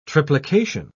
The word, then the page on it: triplication: British English